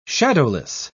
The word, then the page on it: shadowless: British English